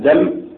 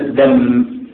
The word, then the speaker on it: دم: وطبعا كلمة دم مخففة الميم، ليست كما ينطقها أغلب الناس دم بالتشديد، لكن هي دم بالتخفيف إلا منه غية ضعيفة.